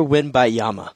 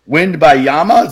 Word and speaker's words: Wembanyama: Victor Wembanyama. Victor Wembanyama. – I think there's another syllable in there. Wembanyama? Is that what you're saying? – Wembanyama.